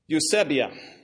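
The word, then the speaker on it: Eusebia (εὐσέβεια): I'm going to give the pronunciation because I pressed pronounce on my Greek in my electronic Bible that I read out of every day. I pressed it about five times, and so this is what you get. It's Eusebia, I think, is the word.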